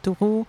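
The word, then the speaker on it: Thoreau: Donc euh, il est question aussi d'Hubertine Claire, de Henri David Toro, euh, je prononce euh, à la française.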